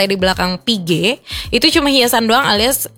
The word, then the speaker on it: Piguet: Jadi intinya Piguet ya dibacanya, Audemars Piguet.